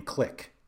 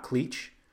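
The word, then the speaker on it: Klich: away that you pronounce his name Click and not Cleach, and it actually made me like him even more.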